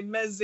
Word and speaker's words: meze: Is it meze? – Different cultures say different. Some people say meze, in Greece it's mezedes. You know, some cultures pronounce it differently, but that's a good universal one.